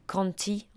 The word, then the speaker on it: Conte: En fait, je pense que ça se prononce Jack Conti en anglais. Ça s'écrit comme un conte en français.